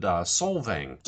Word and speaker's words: Solvang: Again, looking over some of your recent posts on your website, you visited Solvang.